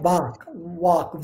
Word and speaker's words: Vak: The third one is Samyak Vak, V-A-K, but V in Sanskrit is pronounced like a W.